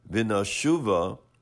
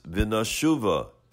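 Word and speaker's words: Vinashuva (ונשובה): The mepharshim explain that the word Vinashuva is spelled without the hay, where really the pronunciation is Vinashuva and not Vinashuv.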